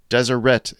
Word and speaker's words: Deseret: I think I corrected it to Deseret. No, it's Deseret. And for the third time now, Laura has corrected us. Deseret is the proper way to pronounce that.